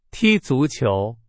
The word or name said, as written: 足球